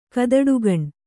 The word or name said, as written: ಕದಡುಗಣ್